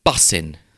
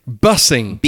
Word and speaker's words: bussin': Bussing. – Bussing. – B-U-S-S-I-N apostrophe. I presume there's a G that's missing. – Yes. – Bussing. – Which they don't pronounce.